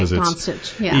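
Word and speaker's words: Dončić: The Lakers, they've been the Luka trade, Luka Doncic, it took a while to really practice his pronunciation. – Right, Doncic. – You know, you look at it and it's Serbian, you know, so it's a different language altogether.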